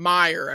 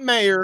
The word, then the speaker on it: Meyer: Meyer, everybody. Not Mayer. – Yeah. – It's Meyer.